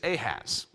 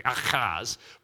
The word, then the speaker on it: Ahaz: But his name is Ahaz. Well, that's how we say it. His name is technically Ahaz, but that's how we pronounce it in the English in our transliteration.